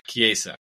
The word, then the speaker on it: Chiesa: PSG are interested in signing Juventus winger Federico Chiesa, according to Gazzetta dello Sport. – How would you pronounce that? – Anyways. – Federico what? – Chiesa.